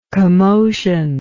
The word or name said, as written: commotive